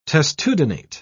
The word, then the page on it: testudinate: British English